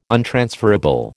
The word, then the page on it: untransferable: American English